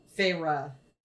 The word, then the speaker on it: Feyre: That once Feyre, is that how you say it? – Yeah.